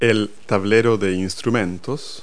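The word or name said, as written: instrument panel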